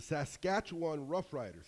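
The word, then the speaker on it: Saskatchewan Roughriders: The last nine games, the Saskatchewan Rough Riders. Did I say that right? – You did.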